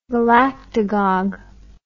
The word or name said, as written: galactagogue